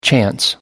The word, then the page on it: chance: American English